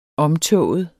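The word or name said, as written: omtåget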